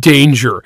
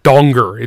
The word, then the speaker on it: Danger: There was a federal agent named Jack Danger. And then they met him, and he was like, he actually goes by Jackie. And it's actually pronounced Donger. It's Dutch. So it went from Jack Danger, and everybody's all excited to meet Jack Danger, to Jackie Donger, you know.